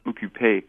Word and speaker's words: occupé: There are French people who complain about people writing the three letters OQP, which you would pronounce occupé in French, and occupé means busy.